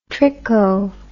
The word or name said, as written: tricho